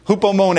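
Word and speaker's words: Hupomone (ὑπομονή): You have hoop and mone. Okay? Hupomone. That's how you pronounce it in the Greek.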